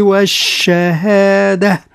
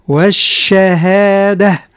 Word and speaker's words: والشهادة: عالم الغيب والشهادة، يعني نطق جيدا حرف الشين. – نعم. – والهاء لأنه حرف ضعيف، والشهادة، والتاء المربوطة التي نقف عليها تنقلب إلى هاء، والشهادة وليست والشهادة.